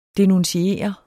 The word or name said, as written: denuntiere